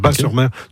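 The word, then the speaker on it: Batz-sur-Mer: On prononce bas, le T ne se prononce pas, Bas-sur-Mer.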